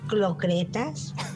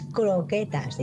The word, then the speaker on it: croquetas: A ver, yo es la típica palabra de clocretas, ¿vale? Y hasta hace poco me dijeron, es croquetas. Digo, madre mía. Y además casi todo el mundo lo pronunciamos mal.